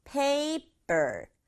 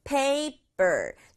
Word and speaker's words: paper: 세 박자 안 하도록 조심해요. paper. paper. 두 박자예요.